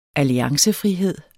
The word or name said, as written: alliancefrihed